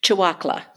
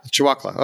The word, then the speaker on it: Chewacla: Now, you mentioned earlier, and I'm going to say this wrong probably, Chewacla Park? – Chewacla. – Chewacla.